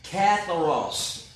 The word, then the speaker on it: katharos: The word clean in the Greek is katharos. Katharos. It is spelled K-A-T-H-A-R-O-S. I believe it was katharos. I have a little Bible app that I use. It tells me the Greek pronunciations or the Hebrew pronunciations. And the guy that speaks them, I try to remember, but I can't always remember how he pronounces. But katharos, or it might be katharos, katharos maybe.